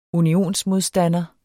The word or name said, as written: unionsmodstander